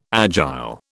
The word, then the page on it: agile: British English